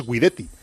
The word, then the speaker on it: Guidetti: Pedro Martín dice que si el apellido Guidetti se pronuncia en italiano es Guidetti.